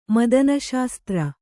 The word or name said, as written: ಮದನಶಾಸ್ತ್ರ